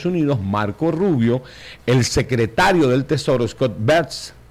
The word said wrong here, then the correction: Bessent: así se pronuncia, es Scott Bessent,